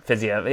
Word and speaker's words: Fiziev: And Fiziev is I'm saying it right, Fiziev? – Yeah, Fiziev. Yeah, I think it's Fiziev.